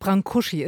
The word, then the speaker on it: Brâncuși: Brâncuși ist die richtige Aussprache. – Brâncuși? – Ja, ich habe ihn auch immer als Brâncuși abgespeichert gehabt. Aber jetzt, wo diese Ausstellung da ist, wird er endlich mal richtig ausgesprochen.